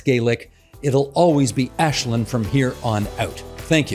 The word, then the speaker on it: Aislinn: Her name is pronounced Ashlyn. It's Gaelic. I know many people didn't know how to pronounce it on election day either. They just needed to know where she was on the very long ballot. For future reference, remember, it's Ashlyn."